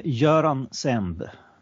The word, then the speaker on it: Göran Semb: Uh, Göran Semb. – Um, but you you were very close. It's not an easy. Swedish names are generally not easy for an English-speaking palate.